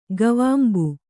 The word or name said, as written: ಗವಾಂಬು